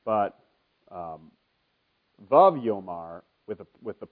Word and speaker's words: vav: In Hebrew, there's a letter, which I can't even draw for you, but the English word of it is W-A-W, and you want to say waw when you see it, but it's actually pronounced vav, like in rhyming with love.